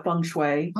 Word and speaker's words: feng shui: Here in the West, they call it feng shui. – Feng shui.